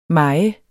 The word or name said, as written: mejer